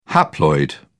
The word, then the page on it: haploid: British English